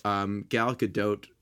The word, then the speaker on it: Gal Gadot: So Gal Gadot, that's the how we decided to, that's how it is correctly pronounced.